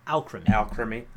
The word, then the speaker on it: Alcremie: It is a pun on the term alchemy, so it should be pronounced Alcremie.